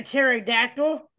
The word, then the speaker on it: pterodactyl: Why can't you hear a pterodactyl going to the bathroom? – What? – Because the P is silent.